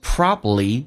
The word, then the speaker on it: probably: Probably the most important thing. 第一个单词 probably, 第二个 b 可以不发音，在美式发音叫 probably。第二个是 important, t 也可以，第一个 t 不发音叫 important, 你要能听懂。